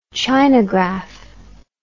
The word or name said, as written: chinagraph